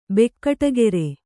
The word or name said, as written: ಬೆಕ್ಕಟಗೆರೆ